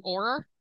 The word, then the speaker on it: Auror: And for him being I'm going to say it wrong. Auror. – Yes. – Yeah. – Good job.